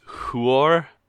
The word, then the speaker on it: Huor: and Huar died in the the Battle of Unnumbered Tears or whatever it was called in the previous chapter. – I do believe it is pronounced Huor and Tuor.